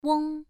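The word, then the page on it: weng: tone 1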